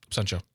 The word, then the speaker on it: Absentia: Season 3, episode 24, Absentia? Did I say it right? – Absentia? – Absentia? – Absentia. – Thank you.